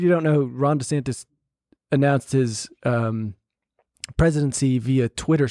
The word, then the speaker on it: DeSantis: After, by the way, it's DeSantis. Apparently, that's how he pronounced it himself, his own name.